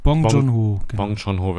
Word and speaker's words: Bong Joon-ho: Das ist ein Film aus Südkorea von 2003, von dem hochgelobten Regisseur Bong Joon-ho. Ich weiß gerade nicht, ob das so ausgesprochen wird. Bong Joon-ho wird ausgesprochen, der auch mit Parasite vor 2 Jahren, glaube ich, extrem abgeräumt hat.